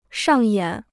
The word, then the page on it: 上演: shàng yǎn